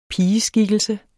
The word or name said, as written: pigeskikkelse